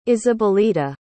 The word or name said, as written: isabelita